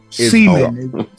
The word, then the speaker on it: Seaman: Seaman. – I was trying to get a bit for that. Yeah, Canyon Seaman. – No, it's pronounced exactly like you think it is. Exactly how it looks.